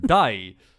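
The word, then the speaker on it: Dai (大): Ja, en nu wordt het ingewikkeld, want het eerste teken van Osaka spreek je dus in Osaka uit als O. – maar omdat het voor Hatsu werd gezet, veranderde de uitspraak van O naar Dai.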